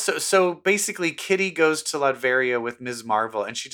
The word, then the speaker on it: Latveria: How do you say that? Latveria? Latvia? – Latveria.